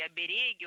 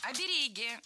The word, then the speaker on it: оберег: Раньше допускалось ударение только на первом слоге – оберег, а сейчас возможны два варианта – оберег и оберег.